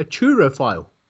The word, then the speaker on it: turophile: And if you love cheese, you are actually called a turophile. – Oh. – Pardon me? – T-U-R-O. File, P-H-I-L-E. – Is it Turo or Truro? – Turo. Turophile. – Spell it again. – Turophile. T-U-R-O. P-H-I-L-E.